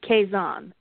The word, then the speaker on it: Kaizen: It's pronounced Kaizen.